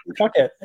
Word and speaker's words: Phuket: Phuket. Fuck it. It's a city in Thailand.